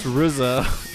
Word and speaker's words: RZA: David says it's pronounced RZA, which is correct.